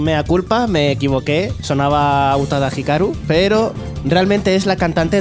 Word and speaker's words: Utada Hikaru: Itada Hikaru ¿Lo he dicho bien? Utada Hikaru Utada, Hikaru.